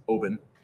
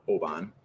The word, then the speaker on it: Oban: This is another one that is often mispronounced. It's Oban, not Oban, which I did probably up until about 10 years ago.